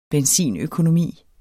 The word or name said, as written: benzinøkonomi